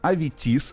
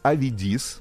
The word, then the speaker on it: Аветис: Значит, алхимик и кузнец по имени Аветис, Авидис, ну вот два варианта, значит, произношения этого имени, имени жил в армянском квартале Константинополя.